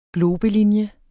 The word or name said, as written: globelinje